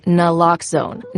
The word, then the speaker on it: Naloxone: For the record, it's Naloxone. Nah, like, is Naloxone addictive? Nuh-uh. Lox like Naloxone keeps humans safe like locks keep bikes safe, and own like Naloxone can save lives in our very own communities.